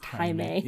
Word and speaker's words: Jaime: Also, I said Jamie, and I meant not to do that. I'm sure it's pronounced Jaime.